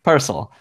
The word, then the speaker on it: Purcell: How was it pronounced? – Mm-hmm. Yeah, that's right. Purcell. – Purcell.